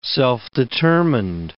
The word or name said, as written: self-determined